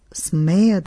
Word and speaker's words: смея: Аз смея да кажа. Учителят го изговори с ударение на смея.